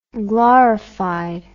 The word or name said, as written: glorified